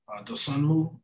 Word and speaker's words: Dosunmu: Dosunmu, Ayo Dosunmu.